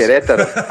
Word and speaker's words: Querétaro: Querétaro. Oh, there you go. Fantastic. Perfect. Thank you. – Perfect. Querétaro, and uh, then Fluminense, and both times were were fairly critical of.